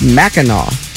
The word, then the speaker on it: Mackinac: I thought Kevin's reading of the text was perfect, but apparently Mackinac Island is pronounced Mackinac. – Yeah, I did not know that. So now I do. – Nice. – Yeah. I mean, that's like St. Louis pronunciations as well. I mean, how would you know to pronounce it Mackinac?